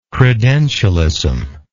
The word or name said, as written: credentialism